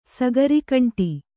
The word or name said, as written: ಸಗರಿಕಂಟಿ